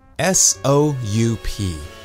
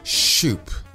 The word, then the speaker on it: soup: The word soup is made up of three sounds: s, u, and per. Many people pronounce it as shoop with a sh sound instead of a s sound.